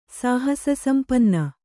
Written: ಸಾಹಸಸಂಪನ್ನ

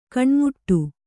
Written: ಕೊನೆಮಟ್ಟಿ

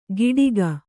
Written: ಕೀಡಿಗ

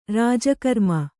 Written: ರಾಜಕರ್ಮ

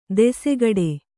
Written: ದಾಸಗೌಡ

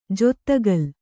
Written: ಜಾದುಕಲ್

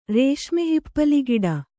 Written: ರೇಷ್ಮೆಹಿಪ್ಪಲಿಗಿಡ

